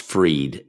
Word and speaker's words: Fried: 13 days later, Sam Bankman Fried, and that's probably pronounced Freed, F-R-I-E-D, Sam Bankman Fried, son of Barbara Fried, Stanford professor and co-founder of political fundraising organization Mind the Gap, launches FTX crypto exchange.